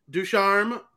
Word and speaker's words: Ducharme: Canadians fire coach Dominique Ducharme.